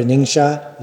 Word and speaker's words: Ningxia: I really want to go to Ningxia.